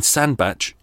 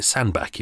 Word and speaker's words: Sandbach: hiccups in Sandbach in Cheshire. You can pronounce it Sandbach if you like as well.